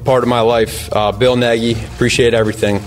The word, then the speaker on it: Nagy: Everyone that talks about him calls him Nagy. And then I saw Patrick Finley tweet out that he asked him, what is the pronunciation of your name, and he told him Neggy, N-E-G-G-Y.